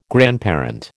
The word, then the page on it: grandparent: American English